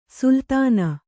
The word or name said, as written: ಸುಲ್ತಾನ್